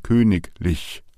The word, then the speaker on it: königlich: Dieses am Ende an der Silbe hat aber die Einschränkung, sollte eine Silbe mit ICH folgen, bleibt das IG IG. Es heißt also zum Beispiel königlich, aber es heißt Heiligkeit.